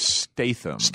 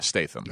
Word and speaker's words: Statham: But yeah, Charlize Theron and Mark Wahlberg, and Jason Statham, is that how you pronounce it? – Statham? Yeah, Statham. – Okay. – No R.